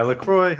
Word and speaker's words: LaCroix: I'm going over to is it LaCroix? How do you say that? – Yeah, LaCroix. I think it's LaCroix.